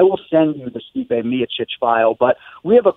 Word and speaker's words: Miocic: So long story short, this is one that we've been battling for a while and will continue to battle, but the Croatian pronunciation, the way he says it on tape for us, is Miocic with the emphasis on the first syllable.